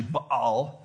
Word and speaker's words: Baal: Yeah, so sometimes we say Baal. Some people pronounce it Baal. It's actually Baal.